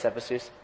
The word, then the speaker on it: Cephisus: Narcissus was the son of Cephisus, however you want to pronounce it. I'm sure it's Cephisus. He's the son of the river god, Cephisus.